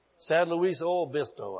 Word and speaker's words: San Luis Obispo: San Luis Obispo.